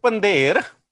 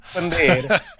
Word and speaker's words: Pandher: It is Pandher. – Oh, wow, that's a tough one. – Pandher.